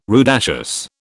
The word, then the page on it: rudaceous: British English